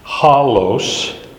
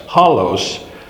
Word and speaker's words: holos: That word, holos, H O L long O S, holos, does mean the word actual or actually, and it removes any question about the truth of the, the, the succeeding statement.